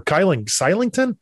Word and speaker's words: Kylington: They still have to sign Oliver Kylington. He's got a weird name. I pronounce his name. It's like K-Y-L-I-N-G Kylington, I think it's called.